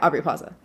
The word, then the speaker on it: Aubrey Plaza: Yeah, Aubrey Plaza.